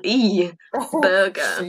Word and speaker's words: Schneeberger: And it's spelled Schnee. As in, like, Schnee burger. Schnee burger, schnee.